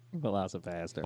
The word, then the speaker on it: VelociPastor: Velociraptor. Doing his thing. – I said it wrong again? – Yeah. – God damn it. – Velocipastor.